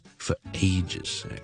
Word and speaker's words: for ages: 上一集咧，我哋讲过好耐冇见咧，我哋或者好耐好耐冇做一件事，我哋可以话咧，we haven't done something for ages, for ages, okay？咁你听到个发音咧？是 foray 咧，好似个 for 嗰个 for 咧，系楞埋后面个 ages 个 a 咧，中间好似有个 o 用咁嘅。